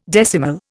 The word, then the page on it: decimal: Indian